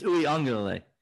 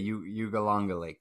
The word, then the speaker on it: Uiagalelei: It's Uiagalelei. – Okay, Uiagalelei.